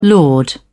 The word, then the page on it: laud: British English